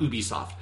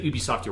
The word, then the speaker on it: Ubisoft: That's how I know that it's pronounced Ubisoft and not Ubisoft. And if you've ever said Ubisoft, you're wrong. – That is so Canadian.